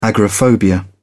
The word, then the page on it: agoraphobia: British English